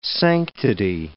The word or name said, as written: sanctity